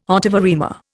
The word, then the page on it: ottava rima: Indian